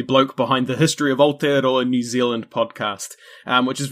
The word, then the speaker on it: Aotearoa: It is me and Christian here with our very good buddy, Thomas Rillstone from the History of Aotearoa.